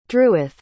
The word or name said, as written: druith